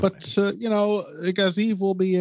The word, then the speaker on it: Gaziev: I haven't decided how I'm pronouncing it. – I think it's Gaziev is how they're... – That's what I've heard.